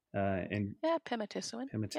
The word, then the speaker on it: Pematisuin: For your theoretical framework, the image that Patrick was able to create in relation to what you described, and I don't know if I'm saying this properly or not, Pematisuin? – Pematisuin. Yeah, you got it right.